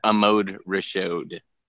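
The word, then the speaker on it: Ahmad Rashad: I believe it's pronounced Amode Rashad.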